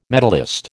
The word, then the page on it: medallist: American English